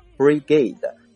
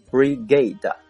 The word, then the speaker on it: Brigade: 下一个单词呢就是旅，它读作 Brigade Brigade，拼成 B-R-I-G-A-D-E B-R-I-G-A-D-E。